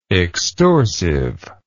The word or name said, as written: extorsive